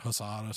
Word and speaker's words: Guisada: Carne Guisada, I think is how you say it, or Husada, something. I don't know the proper pronunciation. But it's G-U-I-S-A-D-A.